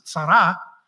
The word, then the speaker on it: tsara: It's tsara, is how you say that.